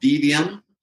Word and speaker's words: Devium: Keith, my production winemaker, has a new label called Devium. And he, well, how do you spell that one? – D-E-V-I-U-M, Devium.